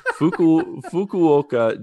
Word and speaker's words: Fukuoka: I have to be really careful how I pronounce this. But if you ever want to visit Fukuoka, Japan, it's Fukuoka. I had to really think about this.